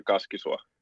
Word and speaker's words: Kaskisuo: You want the Finnish version? Kazimir Kaskisua.